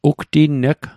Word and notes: ugtinneg: Mi'gmaq

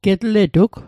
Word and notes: getletug: Mi'gmaq

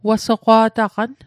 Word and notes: wasoqwa'taqan: Mi'gmaq

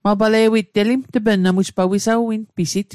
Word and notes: wisawinpisit: Mi'gmaq

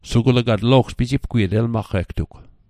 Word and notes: pijipgwiet: Mi'gmaq

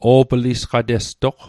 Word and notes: o'plisqatestoq: Mi'gmaq

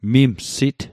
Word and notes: mimsit: Mi'gmaq

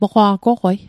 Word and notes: moqwa' goqwei: Mi'gmaq

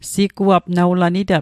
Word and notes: siguap: Mi'gmaq

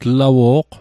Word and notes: tlawo'q: Mi'gmaq

